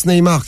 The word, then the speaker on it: Neymar: Eh ouais, quand on connaît le football, on prononce Neymar, carrément.